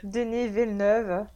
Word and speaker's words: Denis Villeneuve: It's the new Denis Villeneuve movie. I believe that's how you yep. pronounce his name. Leah's like shaking her head at you, being the French speaker that she is. Denis Villeneuve.